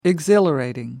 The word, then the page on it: exhilarating: American English